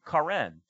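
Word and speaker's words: Karen (קרן): karen. The Hebrew word is associated to horns, and you would pronounce it Karen with E's instead of A's. And so it's Karan and Karen.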